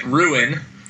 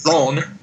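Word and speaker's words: Rouen: And it's, you and I would pronounce it Ruin. – Yeah, but yeah. I'm pretty sure they pronounce it Rhone.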